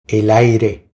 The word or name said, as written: El aire